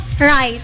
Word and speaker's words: write: Jadi huruf W-nya itu silent. – Oh, silent. – Jadi bacanya right.